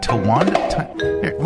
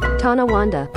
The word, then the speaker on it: Tonawanda: Tonawanda.